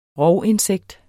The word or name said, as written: rovinsekt